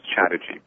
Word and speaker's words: Chatterjee: It's actually pronounced, it's not pronounced with spells, which makes it a little complicated. It's pronounced Pratap, and the last name is Chatterjee, but yeah, sorry about that.